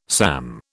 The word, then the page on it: SAM: British English